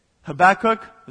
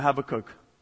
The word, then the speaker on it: Habakkuk: But in I always say this wrong. Habakkuk, is that right? Or Habakkuk? Habakkuk. It's different in Portuguese.